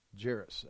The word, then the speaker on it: Jairus: And then Jairus, I looked this up to make sure I say it right. Jairus.